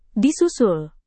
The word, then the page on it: disusul: Indonesian